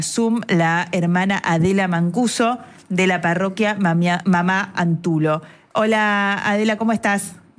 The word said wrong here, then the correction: Mamá Antula: Buenas tardes, Mamá Antula, discúlpame. – Sí, Mamá Antula, ¿lo dije mal? – Había escuchado Antulo.